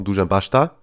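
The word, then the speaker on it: Dušan Basta: Und natürlich, du hast es gerade vollkommen richtig ausgesprochen, Dujan Basta.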